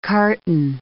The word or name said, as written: carton